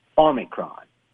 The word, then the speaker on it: Omicron: They say the proper pronunciation is Omicron, with the first syllable pronounced like the word A-W-E, Omicron. Not O, Omicron.